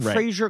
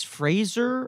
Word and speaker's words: Fraser: We're saying it like Fraser Crane, but he's Fraser.